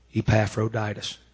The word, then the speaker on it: Epaphroditus: And he found out that it is pronounced Epaphroditus.